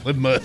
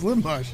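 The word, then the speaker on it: livermush: I even say it right. Lib Mush. – Lib Mush. – It's Lib Mush.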